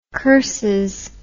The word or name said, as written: curses